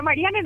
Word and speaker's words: Mariane: Porque muchos es Marian, me ponen ahí unos nombres muy sofisticados, pero Mariane nomás así como suena.